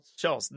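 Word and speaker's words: Schulz: Schultz, no T. – There's no T.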